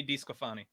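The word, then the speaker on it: DeSclafani: Anthony DeScalafani, I believe. – Anthony DeScalafani. – Yeah, sure. That works.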